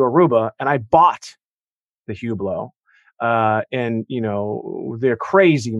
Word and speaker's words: Hublot: A Hublot? And he goes, no, it's a Hublot.